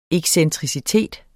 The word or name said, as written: excentricitet